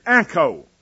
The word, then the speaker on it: Acco: John Gill and other scholars point out that many would translate there this word Anko. A-C-C-O is the way it's spelled.